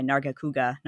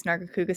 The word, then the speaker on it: Nargacuga: that's why I liked my Nargacuga. Narga, did I have it pronounced? Narga yeah. Kuga.